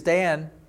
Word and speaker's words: Dan: So what you see as Dan is actually pronounced Dan.